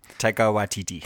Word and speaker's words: Taika Waititi: Someone Watiki. Can't pronounce that guy's name. – Taika Waititi.